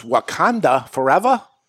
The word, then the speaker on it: Wakanda Forever: Number five, you got to help me with this, is Wakanda Forever.